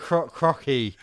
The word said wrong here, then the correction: Croce: How would you say that? – Croce? – Croce. Don't mess with Jim.